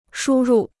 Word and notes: 输入: shū rù

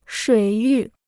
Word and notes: 水域: shuǐ yù

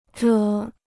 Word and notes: 着: zháo

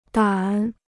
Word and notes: 胆: dǎn

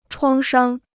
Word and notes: 创伤: chuāng shāng

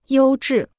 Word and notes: 优质: yōu zhì

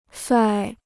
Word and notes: 帅: shuài

